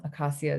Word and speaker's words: Akacia: Akacia is spelled A-K-A-C-I-A.